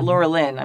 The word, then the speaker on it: Laurelin: lorian the golden tree what, what I named my kid. Mm-hmm. so Laurelin. Oh, sorry. Yeah, Laurelin. I'm sorry. I've been saying it wrong this whole time.